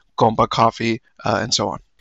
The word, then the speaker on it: Compa: Am I saying that right, Compa? – Yeah, it's supposed to be like compadre, but people just say compa like it's slang.